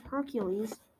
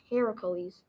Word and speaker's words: Heracles: Most people say Hercules, but he actually originated in Greece. And since his mom's name is Hera, it's pronounced Heracles. Hercules is from the Roman mythology.